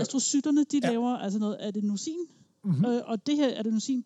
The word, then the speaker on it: adenosin: Altså, inde i hjernekringlekrogene her, din hjerne, mens du er vågen, den producerer et stof, som hedder adenosin. Det ved jeg ikke, om jeg udtaler rigtigt. Altså, adenosin, ikke også? – Adenosin. – Adenosin, ja.